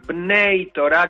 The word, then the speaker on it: Bnei Torah: ¿Dijimos bien, Naitora? – Sí, aquí estábamos en ese conflicto, pero Se pronuncia Bnei Torah, que significan los hijos de la Torah, los que creen en los cinco libros de Moisés.